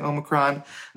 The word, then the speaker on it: Omicron: Some people are pronouncing it Omicron. On this podcast, I'm saying Omicron.